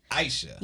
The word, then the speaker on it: Aisha: And how do you say it? – Aisha. – Aisha. – Yes. – Like ISIS, but Aisha.